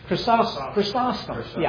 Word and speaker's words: Chrysostom: Chrysostom.